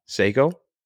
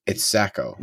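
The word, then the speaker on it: Sako: And you know, we've been pronouncing that wrong forever. – What? Seiko? – It's Sacco.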